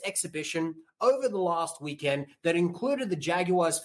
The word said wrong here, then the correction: Jaguares: 15, the Jaguares, I should pronounce it correctly, which is a former Super Rugby side.